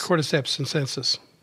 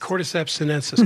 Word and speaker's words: Cordyceps sinensis: Cordyceps sinensis. – Say that again real fast. – Cordyceps sinensis.